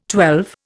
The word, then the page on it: twelve: Indian